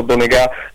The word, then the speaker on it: Donegà: È stato importante, innanzitutto insistere, poi avere dei riferimenti, e io peraltro ringrazio con tutto il cuore il nostro chitarrista Alessandro Donegà, con l'accento sulla A,